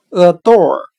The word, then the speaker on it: adore: 我们用谐音法来记忆这个单词 adore，它的发音很像汉语的耳朵。